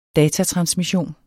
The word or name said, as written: datatransmission